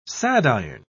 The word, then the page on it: sadiron: British English